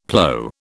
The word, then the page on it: plow: British English